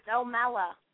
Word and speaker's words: O'Malla: It's O'Malla. – O'Malla. See, I was trying to pronounce the two L's like a Y sound.